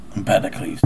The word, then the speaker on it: Empedocles: Empedocles, okay. – Okay. Sorry, people. Doesn't look like Empedocles, but that's how you say it.